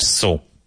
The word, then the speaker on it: sew: S-E-W, but pronounced so, to so.